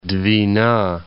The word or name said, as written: Dvina, Northern